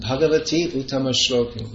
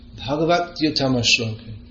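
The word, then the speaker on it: bhagavat uttamaśloka: you're chanting Bhagavati Uttama Shloka, but it's to be pronounced Bhagavat Uttama Shloka.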